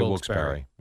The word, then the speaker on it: Wilkes-Barre: It's spelled bar, though. – B-A-R-R-E, yeah. – Yeah. – But they say Wilkes-Barre.